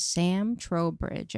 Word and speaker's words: Sam Trowbridge: Our special guest is Sam Trowbridge, everybody.